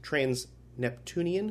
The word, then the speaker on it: trans-Neptunian: And Josh, why were you so surprised about the trans Neptunian? Is that the right word? Am I saying it right? – That's correct. Yeah.